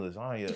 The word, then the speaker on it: lasagna: Like, damn, there really is a G in lasagna. – Facts. – You don't never, it really is silent.